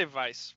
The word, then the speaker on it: Weisz: Is that how you say her name? Weisz? – I say Vice. – Okay. Vice? – I've heard it both ways.